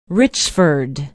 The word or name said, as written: richford